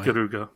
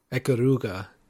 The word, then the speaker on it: Ikaruga: and probably Ikaruga, well, I'm not saying that right, am I? Ikaruga. – Ikaruga, yeah. – Ikaruga.